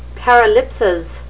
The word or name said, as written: paralipsis